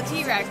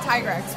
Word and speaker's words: Tigrex: We were not able to kill the T-Rex, which is the official pronunciation now. Not Tigrex, which I've been saying since I was, 14, but, you know, whatever.